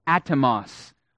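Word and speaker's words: atomos (ἄτομος): And the Greek word that Paul uses here for moment, in a moment, is pronounced atomos, where we get the English word atom or atomic.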